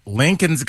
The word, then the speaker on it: Lincoln: Lincoln's got an L randomly in there.